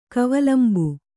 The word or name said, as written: ಕವಲಂಬು